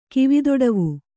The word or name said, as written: ಕಿವಿದೊಡವು